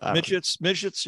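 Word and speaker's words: Micić: So, another thing, any thoughts on the signing of the, how do you pronounce it, Milicic? – I think it's something Micic, but Micic, yeah.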